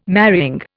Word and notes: marrying: Indian

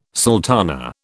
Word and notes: sultana: British English

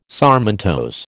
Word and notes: sarmentose: American English

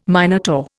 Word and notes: Minotaur: Indian

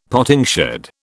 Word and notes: potting shed: British English

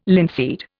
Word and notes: linseed: Indian

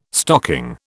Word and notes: stocking: British English

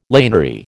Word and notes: laniary: American English

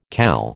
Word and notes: CAL: American English

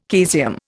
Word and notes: caesium: Indian

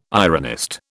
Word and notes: ironist: British English

